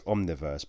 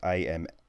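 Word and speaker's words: Amnioverse: And that's Lapalux with I keep wanting to say it's Omniverse, but it's not. It's Amniovese.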